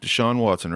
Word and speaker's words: Deshaun Watson: They signed Deshaun Watson, right? Am I saying the name right? – Yeah.